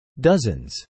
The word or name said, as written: dozens